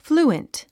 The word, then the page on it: fluent: flúːənt; フルゥエント